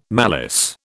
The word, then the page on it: malice: British English